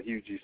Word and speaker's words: Huger: I grew up in this place called Huger, South Carolina, H-U-G-E-R. Pronounced G, yes, it's French.